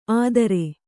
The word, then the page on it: ಆದ್ರೆ: kannada